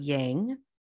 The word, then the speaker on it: yang: The white portion is the yang, and you may notice that I'm saying yang, not yang. This is how it is pronounced. It is pronounced yang.